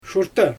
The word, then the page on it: ʃurtɯ: Xyzyl